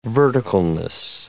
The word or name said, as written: verticalness